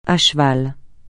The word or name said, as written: à cheval